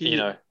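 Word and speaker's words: Iino: Lino. I think Eno. It's actually it's I, double I. Nana C. Eno.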